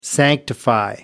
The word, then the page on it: sanctify: American English